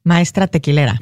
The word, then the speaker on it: maestra tequilera: She was the first woman to be a maestro tequilero. Is that the way you pronounce it? – Maestra tequilera.